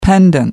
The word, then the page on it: pendent: American English